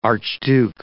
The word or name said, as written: archduke